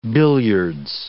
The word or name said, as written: billiards